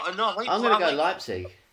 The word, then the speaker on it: Leipzig: We've got how do you say that one? – RB Leipzig. – Leipzig. Yeah. Why did I want to say Leipzig? – Against against Monaco.